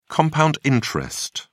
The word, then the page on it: compound interest: British English